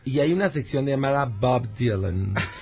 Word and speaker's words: Bob Dylan: Mire, pues tenemos a Bob Dylan y así lo pronuncio. – Bob Dylan.